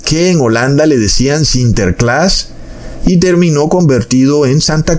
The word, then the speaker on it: Sinterklaas: Porque en el idioma irlandés u holandés se le decía a ese San Nicolás, o sea, así era como se pronunciaba Sinterklaas. Eso pronunciaba San Nicolás. Entonces ese Sinterklaas fue transliterado en el idioma inglés a Santa Claus.